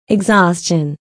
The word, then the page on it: exhaustion: iɡzɔ́ːstʃən; イグゾースチョン